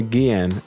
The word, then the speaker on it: Guillen: Jose Antonio Guillen.